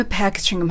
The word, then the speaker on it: packaging: 新しい包装機複数は、 Are the new packaging machines? はい。パッケージングの発音でですね、パの音を少し伸ばし気味に発音すると英語らしくなります。